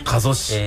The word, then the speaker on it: 加須: 加須市、